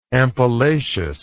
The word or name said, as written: ampullaceous